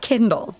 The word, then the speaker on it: Kindal: Note, Kindal is pronounced like Kindle, and she is always holding an Amazon Kindle.